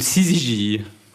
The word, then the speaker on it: syzygie: C'est une syzygie.